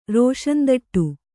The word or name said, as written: ರೋಷಂದಟ್ಟು